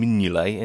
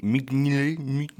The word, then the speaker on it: Mignolet: They were denied by a couple of amazing saves from Simon Mignolet in the Sunderland goal. – Is that really how you pronounce it? – Mignolet. – That's better.